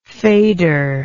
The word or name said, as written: fader